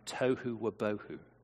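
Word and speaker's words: tohu wabohu (תֹהוּ וָבֹהוּ): Form and void in Hebrew, being the great Hebrew scholar I am, it means pronounced apparently tohu wabohu. Tohu wabohu.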